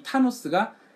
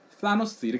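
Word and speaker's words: Thanos: 과거에 타노스, THNOS, 타노스라고 해가지고 나왔는데 이 타노스가 어, TH 때문에 그러는 건지 타노, 과거 그 발음 아시잖아요.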